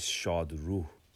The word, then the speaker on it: Shadru: And the correct pronunciation is Shadru. Which, Shad means happy, and Ruh means spirit or ghost or soul.